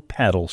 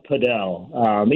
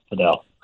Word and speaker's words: padel: It's pronounced padel, even though some groups do call it paddle, but here in the Midwest with people playing platform tennis often call it paddle too. Proper way to pronounce it is padel. – P-A-D-E-L.